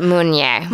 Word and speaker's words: Meunier: Is it Pinot Meunier? – Meunier. – Like a Monet, but Meunier.